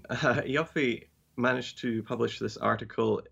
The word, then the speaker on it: Ioffe: And so, but now, no, I guess the reason this article exists is because it's by Julia Ioffe, or how do you say her horrible name, the Russiagate truther lady? – I think it's Ioffe, yeah.